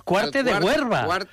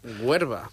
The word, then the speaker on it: Cuarte de Huerva: No es que esté pronunciándolo mal, sino que es que se llama Huerva, la localidad de Zaragoza. – Huerva. – Cuarte de Huerva, sí señor, con R, donde va a jugar el Badajoz, que está aquí cerquita, a 716 kilómetros.